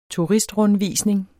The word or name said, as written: TURISTRUNDVISNING